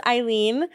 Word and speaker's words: Eileen: So my name is Eileen.